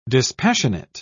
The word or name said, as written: dispassionate